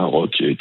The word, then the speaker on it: Rocky: Voilà, Rock-E, avec une prononciation en anglaise.